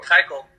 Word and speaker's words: Keuchel: Like, granted, Kuechel, I can't pronounce his last name on the board. Keuchel, God, there it is.